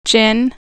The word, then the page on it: gin: American English